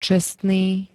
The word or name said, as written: čestný